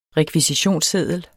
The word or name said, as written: rekvisitionsseddel